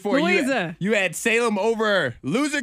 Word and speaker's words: Louisa: It's Louisa. L-O-U-I-S-A.